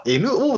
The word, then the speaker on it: N.O.: ノーって読むんですか N ドッ N.O. – って書いて、 NO ですか、あれ。 – あれは NO ですね。 – あ、 NO って言うんだ、あれ。